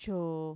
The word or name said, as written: choo